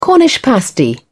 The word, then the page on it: cornish pasty: British English